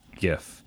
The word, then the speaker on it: gif: I like to say gif. – I like personally. Gif too. – Like, graphical is what that word, you know, that G there is. Graphics. – It's a hard G.